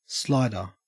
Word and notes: slider: Australian English

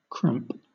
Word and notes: cramp: British English

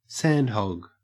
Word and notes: sandhog: Australian English

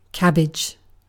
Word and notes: cabbage: British English

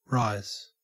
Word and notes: rise: Australian English

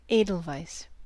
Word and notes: edelweiss: American English

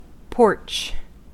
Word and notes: porch: American English

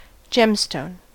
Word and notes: gemstone: American English

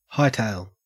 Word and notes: hightail: Australian English

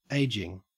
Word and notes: ageing: Australian English

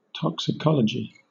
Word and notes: toxicology: British English

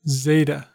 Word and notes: zeta: American English

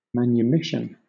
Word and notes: manumission: British English

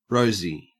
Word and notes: rosy: Australian English

